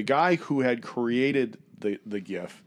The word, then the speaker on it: GIF: It's supposed to be pronounced GIF, which makes no sense to me, and I think that's just completely idiotic. – And he should have spelled it with a J. – Absolutely. It's a soft J, like Yogg.